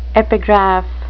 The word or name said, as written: epigraph